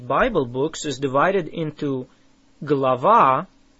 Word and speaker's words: Глава: How do you say a chapter? Глава. Глава. Say ва. Лава. Глава. It starts with a г or g sound. Say it again.